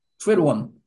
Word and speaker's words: Triduum: Triduum.